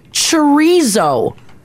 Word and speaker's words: chorizo: I agree, because chorizo is like phonetically.